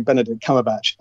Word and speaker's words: Benedict Cumberbatch: Aren't you supposed to be able to pronounce English names? – Yeah, I know, but you never say Benedict Cumberbatch at the very beginning, right? You always have to make a funny version of it.